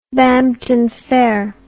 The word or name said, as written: Bampton Fair